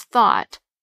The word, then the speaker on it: thought: We know it today as the silent GH in words such as thought, night, and through.